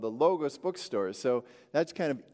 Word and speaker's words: logos: I was actually having a discussion with Keith Elder last week about, you know, pronouncing the O's long or short. I believe technically it's logos, but some people, you know, argue it could have been logos.